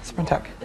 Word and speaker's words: Sprintec: Sprintec. S-P-R-I-N-T-E-C.